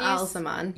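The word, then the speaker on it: Ba'alzamon: This is not great news to Rand, who continues to deny, but I never say his name right. who continues Baal-Zamon.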